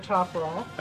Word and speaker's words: metoprolol: He went through 10 chelations, and now he's off all medication except for, metoprolol, or how do you say it? – Metoprolol. – Metoprolol. – Yeah.